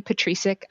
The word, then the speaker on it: Petricek: My last name is spelled PETRICEK. And I told Leslie today, if she forgets how to spell it, it's the word pet, the word rice, and the letter K.